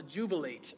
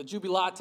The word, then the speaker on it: Jubilate: Psalm 100 is called the Jubilate, or the Jubilate, depending on your pronunciation there.